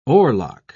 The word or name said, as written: oarlock